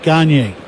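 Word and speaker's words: Gagné: Now Eric Gagne, was it pronounced Gagne or Gagne? – Gagne.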